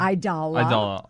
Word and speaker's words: Aidala: Aidala.